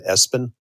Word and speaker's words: ESPN: Now, do you know the story about right after they came out, a couple actually named their son Espen? – Oh, I heard about that. – Nuts. – It's spelled ESPN.